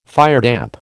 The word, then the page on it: firedamp: American English